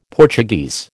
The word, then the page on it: Portuguese: American English